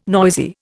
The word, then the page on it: noisy: Indian